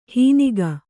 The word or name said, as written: ಹೀನಿಗ